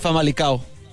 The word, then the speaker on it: Famalicão: Pronúncialo, ¿cómo era? – El Famalicão. – Famalicão. Ah, okay.